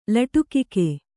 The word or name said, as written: ಲಟುಕಿಕೆ